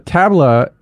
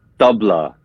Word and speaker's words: tabla: So tabla, is it, so the pronunciation, I want to make sure I'm saying everything right. So tabla, is it tabla or tabla? – It's the tabla.